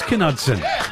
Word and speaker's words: Knudsen: No, it was Knudsen. – Knudsen. – Close. Yeah, close. It's Danish. – Oh, oh no. – I knew it was Dutch. – Do you get that a lot, the silent K? – Yeah, I've had it all the time.